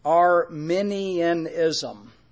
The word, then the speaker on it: Arminianism: Let me spell it. A-R-M-I-N-I-A-N-I-S-M. Arminianism. All right.